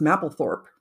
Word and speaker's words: Mapplethorpe: So, and that reminds me that I believe the name is pronounced Mapplethorpe.